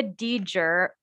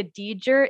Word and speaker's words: Dedear: The problem with Mia Deeger is that it is not spelled how it is pronounced. It's spelled D-E-D-E-A-R. So it's like impossible to remember, impossible to pronounce, impossible to say.